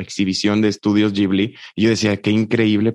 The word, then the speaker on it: Ghibli: Oye, y si no me equivoco, esto es de Estudio Ghibli.